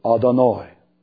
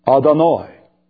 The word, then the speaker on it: Adonai: And when the Jews would come to YHWH, they would pronounce it Adonai. Adonai.